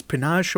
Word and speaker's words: panache: Is panache a word? – Yeah, it is. Good for you. – Panache. Am I saying that right? – Yeah, you got it, man.